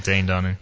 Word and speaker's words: Dane Dunning: Dan Dunning, however you pronounce that. – Mm-hmm. Dane Dunning.